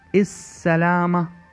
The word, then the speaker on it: السلامة: With some other words, the ill sound is dropped. We don't hear it. Instead, the first letter of the word will be double pronounced, as in the word السلامة.